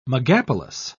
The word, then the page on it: megapolis: British English